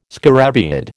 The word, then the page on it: scarabaeid: American English